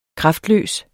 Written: kraftløs

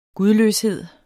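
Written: GUDLØSHED

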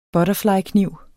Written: butterflykniv

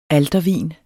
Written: altervin

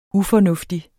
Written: ufornuftig

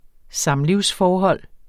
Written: samlivsforhold

